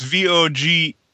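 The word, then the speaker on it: Vögelein: I think it's pronounced Vogelein or Fogelein. It's F. I have to look. I don't know the spelling of it. Which is, it was about a fairy. It's Vogelein.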